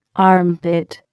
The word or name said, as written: armpit